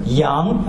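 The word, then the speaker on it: yang (羊): Pronounced as yang.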